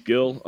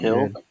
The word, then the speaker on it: Gil: Luis Gil undergoing Tommy John surgery. – Oof. Isn't it Hill? Isn't it pronounced Hill? – Is it Hill? Luis Hill? – I think so. – Maybe. Probably right.